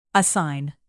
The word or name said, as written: assign